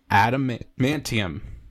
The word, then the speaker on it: adamantium: adamantium.